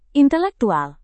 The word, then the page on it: intelektual: indonesian